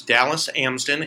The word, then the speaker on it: Dallas Amsden: So my name is Dallas Amsden.